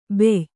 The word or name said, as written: ಬೆಯ್